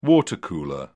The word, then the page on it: water cooler: British English